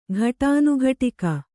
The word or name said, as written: ಘಟಾನುಘಟಕ